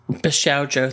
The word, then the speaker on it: Bishoujo: Bishoujo, thank you.